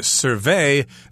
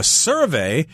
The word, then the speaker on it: survey: And we should focus a little bit on this word survey here because it is a verb. So we've got the accent on the second syllable. The chameleon can survey a wide area for a potential meal. A survey is the noun form with the accent on the first syllable.